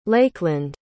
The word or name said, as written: Lakeland